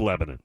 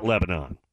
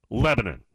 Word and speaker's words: Lebanon: It's actually pronounced Lebanon, Tennessee. Not Lebanon. Lebanon.